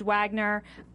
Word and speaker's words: Wagner: he loved Wagner. – It's pronounced Wagner.